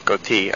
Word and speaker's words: Gauthier: His name is Laurent Gauthier. Gauthier. I was told that I could pronounce it as. Yes, I'm getting a silent applause here from Brett for my wonderful pronunciation.